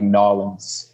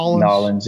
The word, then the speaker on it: New Orleans: I think they say it like Nolens. – Nolens, yeah.